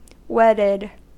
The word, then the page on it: wedded: American English